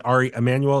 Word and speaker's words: Ari Emanuel: am I pronouncing Ari Emanuel? Is that I'm pronouncing it right? – Yes, yes.